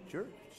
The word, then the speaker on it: Cjuric: I think I'm saying that right. Durek? – Sid Durek. – Sid Durek. – Yeah, so spelt C J U R I C. And as he points out, it's not with a K.